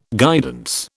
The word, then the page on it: guidance: British English